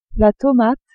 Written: tomate

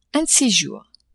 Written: un ces jours

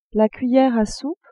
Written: cuillere a soupe

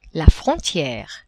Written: frontiere

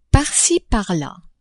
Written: par ci, par la

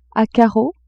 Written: a carreaux

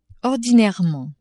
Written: ordinairement

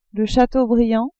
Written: chateaubriand

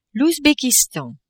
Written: Ouzbekistan

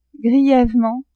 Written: grievement

